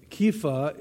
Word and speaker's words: Kepha: And Kepha is a Jewish Aramaic pronunciation of Cephas.